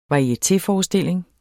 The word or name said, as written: varietéforestilling